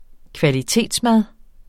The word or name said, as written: kvalitetsmad